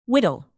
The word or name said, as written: whittle